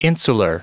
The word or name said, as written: insular